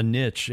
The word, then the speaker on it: niche: So it's not like, you know, you use the word niche or a little earlier, or some people would pronounce it a niche.